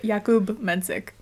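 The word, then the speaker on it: Jakub Menšík: By the way, Jakub Mencik. – Jakub Mencik. Okay.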